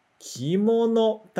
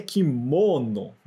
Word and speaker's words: kimono: E aqui é pra gente lembrar que kimono, tá? Se pronuncia desse jeito. Mas quando a gente pensa no português brasileiro, a gente tende a pensar que é kimono, né? A gente tenta colocar o acento bem forte no mo, kimono.